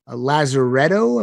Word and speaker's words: Lazaretto: 2 CD copies of Lazaretto.